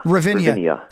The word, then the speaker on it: Ravinia: Is it Ravinia? Had the festival in Chicago that you guys are playing? – Ravinia.